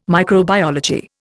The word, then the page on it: microbiology: Indian